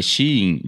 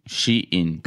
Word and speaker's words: Shein: Eu não falo, nunca falei, mas eu acho que é Shein, eu pesquisei. É Shein, de ela dentro. Shein. – Ah, Shein.